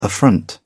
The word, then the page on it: affront: British English